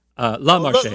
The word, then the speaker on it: La Marseillaise: Les Marseillaises. La Marseillaise.